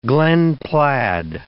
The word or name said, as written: glen plaid